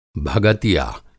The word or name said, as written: भगतिया